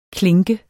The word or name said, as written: klinke